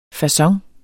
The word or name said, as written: facon